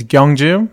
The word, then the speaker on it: Gyeongju: Everyone tried it hard, but like, yeah, my first name is Gyeongju.